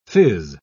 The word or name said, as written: phiz